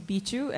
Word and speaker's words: Bihzhu: Okay, my name's Bichu and it's spelled B I H Z H U.